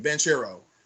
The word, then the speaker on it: Banchero: Banchero.